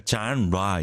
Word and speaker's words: genre: 아까 John Ra 있죠? John Ra. 맞아요. John, the point is Ra. R-E가 라처럼 발음돼서 music genre, ra 이렇게 되더라고요.